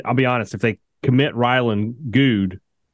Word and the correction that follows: Goode: But I think his name is pronounced Goody. – Goody? Okay. – That is apparently what it is.